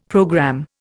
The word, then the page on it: programme: Indian